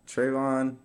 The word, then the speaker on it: Trevaughn: And it's Trevaughn for everyone out there. Like, I feel like, yeah, Trevaughn, you, I feel like a lot of people say your name wrong. – Yeah, I get Trevaughn a lot.